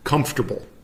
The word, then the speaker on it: comfortable: We just kind of like skip out the second syllable. Or instead of saying comfortable, we just say like comfortable. Comfortable. It's definitely hard to pronounce the way we do it. And there's nothing wrong with pronouncing it fully like comfortable. Comfortable. But comfortable is the way we say it here. And we also have, we use the word comfy, just like C-O-M-F-Y, as a shorter version of it.